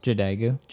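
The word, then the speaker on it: Jadagu: This is Warning Sign by Hannah... Jadagoo. How'd you pronounce it? – Jadagoo. – There we go.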